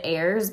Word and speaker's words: Ayers: And it is like in the book she pronounces it Ayers, but I don't know if it's like Ayers, but it's A-Y-E-R-S.